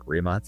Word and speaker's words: Rimac: And I have driven the Rimac Navara, which we would call Rimac Navara, but it's pronounced Rimac.